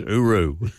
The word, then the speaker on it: hooroo: And the correct way to pronounce that in Australia was without the H. It was always hooroo.